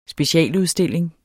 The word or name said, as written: specialudstilling